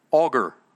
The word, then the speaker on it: Agur: The words of Augur. It says Agur, but it's pronounced Augur. Now, for me, Augur is like a power auger.